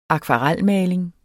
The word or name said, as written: akvarelmaling